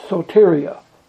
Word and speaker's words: soteria: That Greek word is really soteria. That's what I understand how it's pronounced.